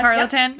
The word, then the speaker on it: Tarleton: The Glinda scenes were shot simultaneous to the January 26th opening scenes of Scarlett O'Hara and the Tarleton twins. Am I saying that right? – Mm-hmm. – Tarleton? – Yep, okay.